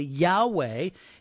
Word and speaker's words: Yahweh: We pronounce it as best as we know how, Jehovah, but it's actually Yahweh, and it is the most sacred name for God that the Jews knew.